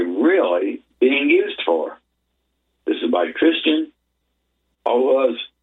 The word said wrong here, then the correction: Orozco: I guess it's pronounced Orozco, O-R-O-Z-C-O, March 22nd, 2026.